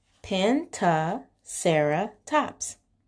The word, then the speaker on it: Pentaceratops: Pentaceratops.